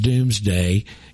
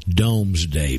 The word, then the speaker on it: Domesday: We pronounce it Doomsday. It's spelled, again, because of this French thing, Dome. D-O-M-E. Domesday Book. Pronounced Doomsday Book.